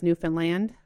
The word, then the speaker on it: Newfoundland: of which we were pronouncing incorrectly for quite a while. It's Newfoundland, with the emphasis on that.